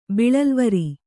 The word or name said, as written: ಬಿಳಲ್ವರಿ